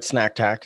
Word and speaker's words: Snack Tack: Is that pronounced right? Snack Tack. That's what you call it. – Yeah, it's like Snack Attack.